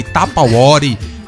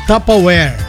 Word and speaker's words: Tupperware: A pronúncia correta é Tupperware, Tupperware, Tupperware. É o quê? – Tupperware. – Tupperware. – Tupperware. O E do final nem é pronunciado. Morre no where.